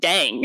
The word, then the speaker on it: Dang: And it's also a way for me to correct them, because one thing that you know, with going to school and whatnot, people will see your last name, question if that's really how you pronounce it, and then pronounce it incorrectly by changing a vowel because they think that's like the Asian way of saying it. And it's like, no, it's just "dang."